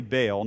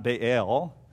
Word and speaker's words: Baal: now I say Baal, not Baal, however you want to pronounce it, and worshipped him.